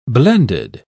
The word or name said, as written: blended